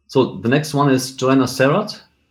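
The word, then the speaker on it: Joana Serrat: I saw her in England at a festival, and she was being introduced as Joanna Serrat, so I'll leave it like that.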